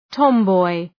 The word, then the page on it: tomboy: ‘tɒm,bɔı